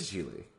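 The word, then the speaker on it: Gigli: Yeah, I thought the second G was silent. It was G. It's G, Jiggly? Jiggly? – Oh, you're right. It is Jiggly. Because it has a pronunciation key. I guess people do that. Why is the second G silent? Whatever.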